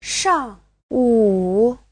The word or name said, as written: shang4wu3